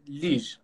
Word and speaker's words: Liège: یگه، اگه اشتباه نتلفظ نکنم، در بلژیک. خیلی خوش اومدید. – لیژ.